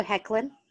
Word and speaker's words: Hoechlin: Can we talk about Tyler Hotchlin ah. and Superman? Okay, so first of all, it's Tyler Hoechlin. Oh, Hoechlin. Hoechlin. Okay, my bad.